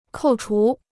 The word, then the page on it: 扣除: kòu chú